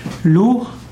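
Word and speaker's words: Lugh: Lu geschrieben mit G am Ende oder GH ausgesprochen Lu ist ein wichtiger Gott der irischen Mythologie.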